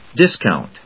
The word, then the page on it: discount: dis・count; dískɑʊnt